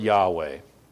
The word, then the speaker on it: Yahweh: This is that Hebrew word Yahweh. Way back in the day, people used to pronounce it Jehovah because they didn't know better, but this is that Hebrew word Yahweh.